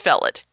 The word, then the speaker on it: Featherilstead: And then there was spelled F-E-A-T-H-E-R-I-L-S-T-E-A-D, pronounced Felid.